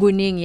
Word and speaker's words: Kuningi: Kuningi, yeah, nice.